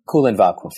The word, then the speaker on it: Ulaanbaatar: I'm wondering if you could explain to the listeners what happened in, I hope I get this pronounced right, Ulaanbaatar. Feel free to correct me when there's time. But Ulaanbaatar, yeah.